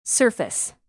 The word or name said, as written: surface